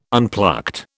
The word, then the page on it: unplucked: British English